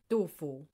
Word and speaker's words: Du Fu: Du Fu.